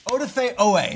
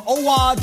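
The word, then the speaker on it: Oweh: My prediction is that not only does he have 2 sacks, but Joe Buck seamlessly pronounces his name correctly, does not call him Owa, does not call him Odafe, and does not mess up his name.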